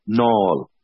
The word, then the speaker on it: Naul: they just spelt it N-A-U-L, Nall, because that's how the locals would pronounce it.